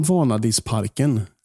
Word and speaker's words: Vanadisparken: Dagens utsikt är över, säger man Vanadisparken? – Ja. – Är det rätt betoning på liksom alltihopa? – Det lät helt perfekt.